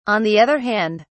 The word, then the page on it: on the other hand: British English